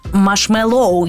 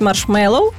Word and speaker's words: маршмеллоу: Машмелоу, я чула, що ти кажеш машмелоу. Так, рухаємося далі. Е, Наступне питання: чи можна зробити машмелоу або зефірку без цукру? – хочу зараз про наголос сказати. Ну немає там точного наголосу, uh-huh. тому там я думаю, що англійці кажуть маршмеллоу, так і взагалі це з перекладу з англійською е, рослина, яка лежала в основі створення ць, ць, ць, цього солодоща.